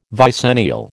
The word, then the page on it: vicennial: American English